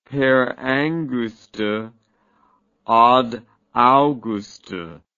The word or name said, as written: per angusta ad augusta